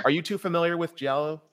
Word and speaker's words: Giallo: Am I pronouncing that correctly? Giallo? – American Giallo.